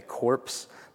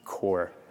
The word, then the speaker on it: corps: It's kind of, I was in the Marine Corps, and corps is spelled C-O-R-P-S, and so when you read it, you want to say corpse, but it's not. It's just core, revelation.